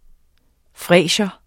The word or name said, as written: fresia